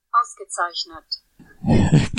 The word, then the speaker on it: Ausgezeichnet: Excellent in German equals ausgezeichnet. Ausgezeichnet. He gave us a phonetic one. – Phonetic link. So it'll play it here, maybe. All right, so here's how you say excellent in German. – Ausgezeichnet.